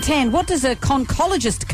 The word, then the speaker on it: conchologist: Conch was the giveaway, but it's pronounced conchologist.